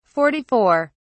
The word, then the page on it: 44: British English